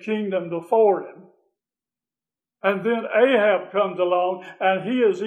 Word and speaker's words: Ahab: Ahab, actually, we in the English-speaking world call him Ahab. The Hebrew is pronounced Akab, which means friend of the father.